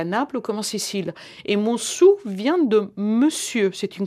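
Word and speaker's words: Monsù: Un mot, des Mensou. Comment prononce-t-on Monsou.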